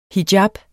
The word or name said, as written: hijab